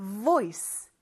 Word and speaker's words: voice: 윗니를 아랫입술에 살짝 대보세요. 이렇게. 그 다음에 V라고 하는 것처럼 입술을 진동시켜 봐요. 이렇게. 맞아요. 휴대전화 진동 벨소리 같은 느낌이죠? 고 소리가 바로 V인데 그거를 살짝 내주는 거예요. 그래서 voice, voice 이게 V 하는 소리가 살짝 들렸죠?